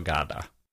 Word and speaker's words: Gadda: Brutto on Via Merulana by Carlo Emilio Gada.